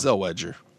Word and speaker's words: Zellweger: I never say her name properly anymore because of that drop. I don't even, I'm not even sure how to say her name properly anymore. – It's Zellweger.